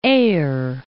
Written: Eyre, Lake

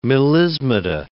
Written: melismata

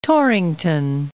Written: Torrington

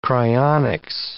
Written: cryonics

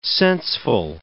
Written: senseful

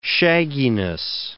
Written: shagginess

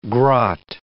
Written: grot

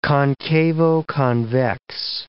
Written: concavo-convex

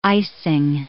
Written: icing

